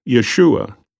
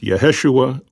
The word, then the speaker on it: Yeshua: which is a variant spelling of Jesus' Hebrew name, Yeshua, alternatively pronounced Yeheshua or Yehoshua.